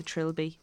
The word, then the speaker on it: trilby: And he wears a trilby. Tribbly or Trill, how do you pronounce the hat? – Trilby, I would say.